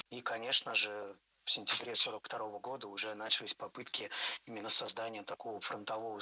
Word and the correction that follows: начались: Не начались, а начались. Ударение ставим опять же на последний слог, в самый конец слова.